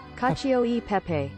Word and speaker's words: Cacio e Pepe: I'm going to attempt to say this, and then I'm going to play you how to actually say it. – Fine. – Cacio e Pepe. And then it actually is said like Cacio e Pepe. – Cacio e Pepe. – Okay, it's close.